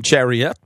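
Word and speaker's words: Chiarot: Oui, alors je crois que c'est Ben Chariot, parce que c'est de la façon dont nous l'a présenté Dominique Saillant ce matin.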